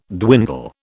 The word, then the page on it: dwindle: British English